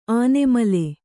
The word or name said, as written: ಆನೆಮಲೆ